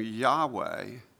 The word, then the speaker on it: Yahweh: You know, God's Hebrew name, Yahweh, is how we pronounce it.